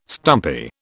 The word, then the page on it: stumpy: British English